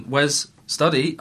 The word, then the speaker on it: Wes Studi: Also, it's important to know that, obviously, Wes Studi, I pronounced his name correctly, is important in both of those movies and is insanely memorable in both roles.